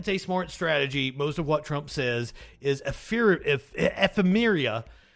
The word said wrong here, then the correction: ephemera: easily dismissed. Ephemera. I did say that right, I'm pretty sure.